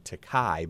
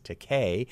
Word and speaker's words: Takei: There's a really charming documentary about him called To Be Takei, and most people mispronounce his name Takai, but it's Takei, and that's playing.